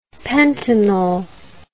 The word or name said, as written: pentanol